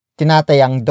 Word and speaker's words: tinatayang: Another way of expressing an estimate value is by using the phrase tinatayang, followed by a number. For example, tinatayang 12, tinatayang 12, tinatayang 12.